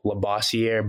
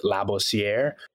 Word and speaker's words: Labossiere: And then, you know, kind of just obviously growing up in America, we just used English pronunciation with Labossier. But, you know, if you're speaking to Haitians, French, you know, they'll know to pronounce it Labossier.